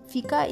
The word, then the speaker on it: fika: Okay, guys, the next word is fika. It's F-I-K-A, and you know, how can you pronounce it? It's fika.